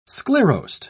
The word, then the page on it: sclerosed: British English